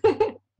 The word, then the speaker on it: Kara: I'm Kara.